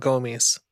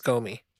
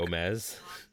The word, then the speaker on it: Gomi: What if we call it Gomi and we call it Gomies? Because trash in Japanese is Gomi. – Like look. Gomez? – Gomi. – I don't hear it. How do you spell it? – Okay. G-O-M-I.